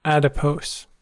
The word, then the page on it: adipose: American English